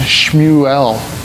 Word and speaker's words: Shmuel: Now, as I put a note there, Samuel, actually in the way they pronounce it, is Shmuel. And it sounds like heard of God.